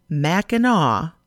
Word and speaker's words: Mackinac: To get to Mackinac Island, and that's pronounced Mackinac, you take a boat from either St. Ignace from the north or Mackinac City in the south.